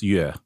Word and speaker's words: Yue: And my last name is the UE sound is a pronunciation that is impossible for Western tongues to pronounce. So I get a lot of UAs and a lot of U's, but it's Yue, which I acknowledge it's hard to pronounce.